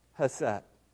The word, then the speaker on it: chesed: Well, we could say she's been changed by God's chesed.